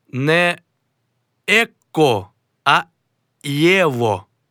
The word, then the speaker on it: его: В інтелігентній мові вимовляють «єво», а не «его» і не «ехо».